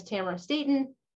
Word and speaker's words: Tamara Staton: As I said, my name is Tamara Staton. It rhymes with Camera Satan.